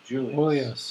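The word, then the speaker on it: Julios: If you approach a place of business spelled J-U-L-I-O-S, J-U-L-I-O-S, pronounce that? – Julius. – Julius. – Julius. – Julius. – Dick. I was calling it Julio's. I was wrong.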